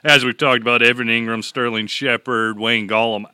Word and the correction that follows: Gallman: out tonight versus the Patriots. – Gallman. – Did I say it wrong again?